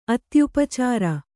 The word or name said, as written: ಅತ್ಯುಪಚಾರ